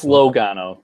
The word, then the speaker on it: Logano: It's Logano. Don't worry, Kevin. You're fine.